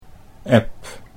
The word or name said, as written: Eb